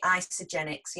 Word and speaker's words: Isagenix: Now, have I pronounced that right? Because I've not heard of this before. So it's Isagenix. – Yeah, absolutely. Isagenix. Yep, you got it right.